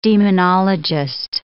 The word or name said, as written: demonologist